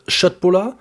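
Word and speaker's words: köttbullar: Wir hatten vegetarische Schöttbulla fertig. – Der Herr, ist ein Kenner, er hat es korrekt ausgesprochen.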